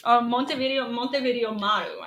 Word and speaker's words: Montevideo Maru: The ship is the Montevideo Moru. – Whatever you said. The big... – Montevideo Maru. I said that wrong.